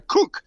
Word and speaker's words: Koch: Es así, yo también le decía que rato Koch, porque así es, no sabía que Koch se pronuncia Cook, pero sí es Christina Cook.